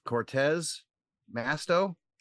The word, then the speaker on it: Cortez Masto: It looks like, as of right now, Cortez Masto, is that how you say it? – Yeah.